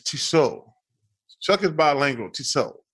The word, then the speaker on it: Tissot: Tissot. Right? The T. Hey, man, don't you have any culture? The T is silent. – None. – The T on the end is silent. It's Tissot.